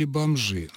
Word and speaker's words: бомжи: Бомжи или бомжи? Ну, конечно, все-таки бомжи.